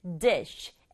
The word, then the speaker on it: dish: 발음은 dish. sh의 소리를 쉬, 바람처럼 내서 한 박자 단어가 되는 거예요. dish 아니고 dish.